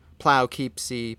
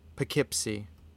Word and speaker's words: Poughkeepsie: And I pronounced it wrong the whole drive there. Plowkeepsie, Poughkeepsie, until eventually you taught my tongue, Poughkeepsie.